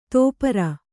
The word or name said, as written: ತೋಪರ